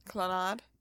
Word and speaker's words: Clannad: The band, called Clannad, I think that's how you say it. – Clannad. – Clannad. C-L-A-N-N-A-D. – Clannad? Yeah, I think that's Clannad.